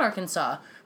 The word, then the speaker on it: Arkansas: It ends with an S, and S. – you don't pronounce it? Arkansas? – Arkansas, yeah. I'm just like, you guys are, like, let's not make this European. Like, there's a silent letter. Like, it's Arkansas.